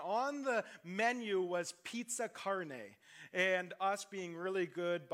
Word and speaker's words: carne: And those who are Spanish or know Spanish or Italian or anything like that will be very familiar, and I'm probably pronouncing wrong with the word carne, right? That's the word? Okay, I'm saying it right, which means flesh or meat.